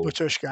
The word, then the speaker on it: Batushka: Seeing them live, how do you spell Batushka?